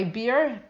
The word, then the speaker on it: Bier: My descendants should not follow my beer. Am I pronouncing it right? B-I-E-R?